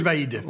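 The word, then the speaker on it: Vahid: Vaid Halilhodžić, Oui, ne te pose pas trop de questions et prononce-le le plus rapidement possible. – Coach Vaid.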